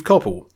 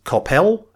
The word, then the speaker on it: Coppell: Apparently, Moro has been wrongly calling Steve Coppell Steve Coppell. Mr. Coppell says it should be pronounced Coppell, as in hell.